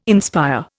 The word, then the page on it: inspire: Indian